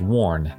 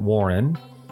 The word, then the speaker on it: WARN: That's WARN, not Warren, but WARN, one syllable, not two. W-A-R-N stands for Worker Adjustment and Retraining Act,